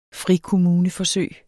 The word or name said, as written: frikommuneforsøg